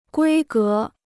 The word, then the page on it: 规格: guī gé